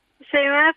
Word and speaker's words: Chaimaa: Ho detto bene il numero, eh, il nome eh, Faiti? – Sì, sì, sì, buonasera. – Lo vorrei sì. sentire ripetuto da lei così poi lo copio. – Chemè Fatih.